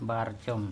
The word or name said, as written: barchom